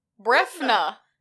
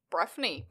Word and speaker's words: Breifne: B-R-E-I-F-N-E. Brefney? – Okay, give it to me one more time. – B-R-E-I-F-N-E. – F-N-E. Let's see what Brid Ellis says on the pronunciation of Briefney. Brefna. – That was close. – I was really thinking it was going to be like Brian. – Brian O'Brien? – Because that's really Brefna. What those Irish names are like. – So this is Brefna O'Brien. He was an Irishman convicted of fraud in 2014. – Wait, we have an opposing pronunciation. Breffni. Breffna? Breffni? Whatever you prefer.